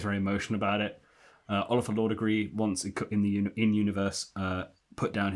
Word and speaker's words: Laughdugry: The name is spelt L-A-U-G-H-D-U-G-R-Y, which of course looks like it should be Laugh Degree or something, but it's not. It's Law Degree. It's Oliver Law Degree.